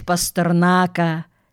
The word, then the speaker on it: Пастернака: Поэтому, если сравнить Пастернака, примечание автора, естественно, с ударением на последнем слоге, со свиньей, то свинья никогда не сделает того, что он сделал.